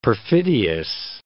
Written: perfidious

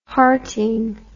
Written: hearting

